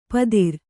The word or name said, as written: ಪತ್ತಿರ